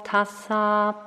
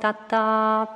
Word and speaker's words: Tassa: And when he had finished the three repetitions, I just started to translate it into the Burmese way of pronouncing the Pali, which is Namo Tata Bhagavato Arahato Tamma Tambo Tata.